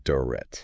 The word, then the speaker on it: Durrett: The author is Don Durrett, and that last name's spelled Durrett. – Pronounced Durrett.